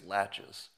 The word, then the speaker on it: laches: Because of the doctrine of laches or laches, L-A-C-H-E-S.